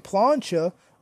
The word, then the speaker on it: plancha: Kofi Kingston with a plateau. Sorry, I said that wrong. Kofi Kingston with a plancha over the top rope.